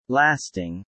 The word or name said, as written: lasting